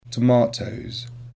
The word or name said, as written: tomatoes